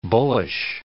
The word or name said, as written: bullish